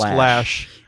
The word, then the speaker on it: Loesch: And Dana is as L-O-E-S-C-H. – But pronounced Lash.